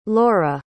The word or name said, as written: laura